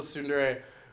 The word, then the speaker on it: tsundere: Is it tsundere? – Tsundere.